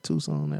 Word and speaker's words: Tucson: And I said that right, Tucson, Arizona.